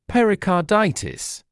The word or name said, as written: pericarditis